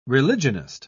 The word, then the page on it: religionist: British English